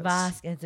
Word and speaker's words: Vasquez: Vasquez.